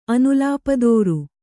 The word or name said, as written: ಅನುಲಾಪದೋರು